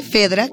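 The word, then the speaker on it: Phaedra: Y Faedra aparece, Fedra, que se escribe P-H-A-E-D-R-A, e Faedra y se dice Fedra, aparece en 2005.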